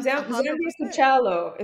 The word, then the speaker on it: Zambia Kuchelo: Come on, Zambia Kuchelo is how you say it.